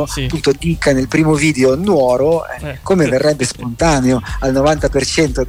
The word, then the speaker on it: Nuoro: Allora, oggi parliamo dell'annoso problema di accento che sì, contraddistingue la città di Rullo di Tamburi Nuoro. – Ah, l'hai detto giusto. Avevo un po' di ansietta nel pronunciare Nuoro, Nuoro, esatto. Non Nuoro.